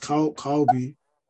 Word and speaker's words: Kaobi: I used to be Ayo, and I changed my name to Kobe, which is pronounced Kaobi.